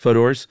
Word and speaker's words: Fodor's: And it's funny, when you sent me the sources for this, Fodor's, how do you pronounce that? – I think you just said it. – Fodor's was one of them.